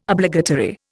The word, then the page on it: Obligatory: Indian